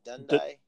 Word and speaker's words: Dundee: Dundee.